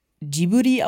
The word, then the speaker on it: Ghibli: Im Japanischen wird es halt Ghibli ausgesprochen. – Ah, okay. Ghibli. – Ja, fast, geil. Ja. Also quasi Ghibli, so. Aber in Japan wird oft das R nicht so klassisch ausgesprochen wie hier.